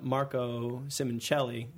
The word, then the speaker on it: Simoncelli: But um, just as that wound was beginning to heal, we got another major blow um, with uh, Marco Simoncelli. I'm probably not saying that right. – Oh, that's it.